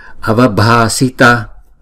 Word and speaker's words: avabhasita: Avabhasita, avabhasita. Chữ b và chữ h ghép lại là b. Và chữ a là hai nhịp.